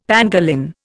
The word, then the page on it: pangolin: Indian